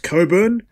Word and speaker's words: Cockburn: I mean, people who are called Cockburn will try and convince you that it's pronounced Coburn, but that's because it's pronounced Coburn, Cockburn, like- and they don't want to be dragged because it's pronounced Cockburn.